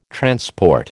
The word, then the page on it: transport: American English